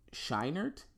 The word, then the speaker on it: Scheinert: Well, it was Dan Kwan and Daniel Scheinert. Is that how you say his name? The two Dans, Daniels. – Yeah. – Correct. Yes.